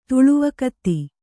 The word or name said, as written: ತಳವಿಘಾತಿ